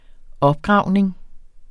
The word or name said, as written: opgravning